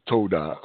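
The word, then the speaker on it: Todah (תודה): Okay, the Hebrew word for Thanksgiving is Todah, pronounced Todah. It's Todah, meaning confession, praise, and thanksgiving.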